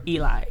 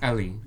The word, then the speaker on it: Ellie: Because people ask me all the time, is it Ellie or Eli? – It's Ellie. Ellie. Okay, you good. Know that. You know I know that, but everyone's always like, "Is it Eli?" Then I'm like, "Have I been saying his name wrong the whole time?" – Right.